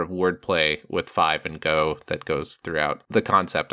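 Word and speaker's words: Go (五): The title in Japanese is Mach Go Go Go, and Go is kind of a pun on the number five is pronounced Go in Japanese.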